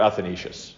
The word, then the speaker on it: Athanasius: St. Athanasius, or Athanasius, as the Western pronunciation.